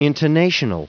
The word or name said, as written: intonational